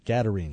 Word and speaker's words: Gadarenes: Gadarenes.